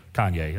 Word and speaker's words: Kanye: Because they may follow Kanye, that's the way you say his name.